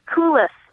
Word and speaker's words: coulis: And then this coulis, I think is how it's pronounced.